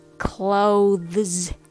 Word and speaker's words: clothes: So you might think you have to pronounce it clothes. But can you hear the th sound in clothes? Actually, not really, because it's hard to say this, this, this.